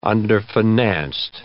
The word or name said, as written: underfinanced